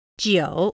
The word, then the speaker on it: 九: And in Chinese, 9 is pronounced jiǒ.